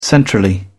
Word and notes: centrally: British English

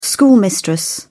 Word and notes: schoolmistress: British English

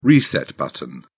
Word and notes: reset button: British English

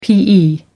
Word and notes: pe: American English

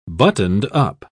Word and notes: buttoned-up: British English

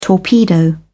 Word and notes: torpedo: British English

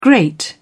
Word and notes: grate: British English

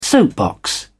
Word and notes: soapbox: British English

